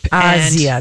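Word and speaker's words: Asia Argento: Azia, and that's how you say it.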